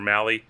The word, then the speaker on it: Mahle: Tyler Mahle, in six innings, or Mahle, keep saying his name wrong.